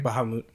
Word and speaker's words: Bahamut: Is that how they pronounce the name in the game? – Bahamut, yeah. – I always thought it was Bahamut.